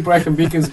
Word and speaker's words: Brecon Beacons: You say Brecon Beacons better than the English people.